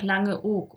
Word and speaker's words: Langeoog: Langes O. – Oog, man sagt ja auch Langeoog, oder? – Langeoog. – Langeoog, ich habe es ja falsch ausgesprochen. – Langeoog. – Und dann haben wir gesagt, das ist ein langes O, was wir da verknüpfen.